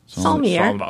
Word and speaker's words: sommelier: Sommelier. – Sommelier is the wine guy. – I'm not pronouncing that right. How do you say that? – Sommelier.